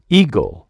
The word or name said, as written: eagle